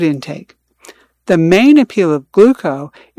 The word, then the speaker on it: Glooko: Gluco. That's spelled G-L-O-O-K-O.